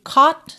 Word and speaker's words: caught: Então, caught up, aqui atenção que caught se escreve C-A-U-G-H-T. E a pronúncia é caught, caught. Pensa num O com acento agudo e depois já o T, sendo que esse T aqui virou rarara, caught up, ok?